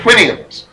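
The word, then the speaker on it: Houyhnhnms: Anyway, my favorite, too, was the land of the Whinians. That's how you pronounce that.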